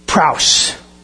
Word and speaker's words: praus: Praus. One syllable. Praus.